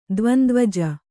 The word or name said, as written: ದ್ವಂದ್ವಜ